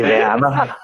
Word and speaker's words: Werner: Jeg skal bare lige høre, altså udtalte du hans efternavn, som var han med i Taxa? – Werner. Werner. – taxa, det er Werner.